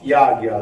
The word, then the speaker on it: yajna: He says, who acts for the sake of yajna, yajna in Sanskrit, pronounced yajna, like ajna chakra is pronounced agya chakra.